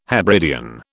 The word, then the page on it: Hebridean: British English